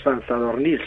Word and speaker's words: San Zadornil: San Zadornil,